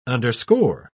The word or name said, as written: underscore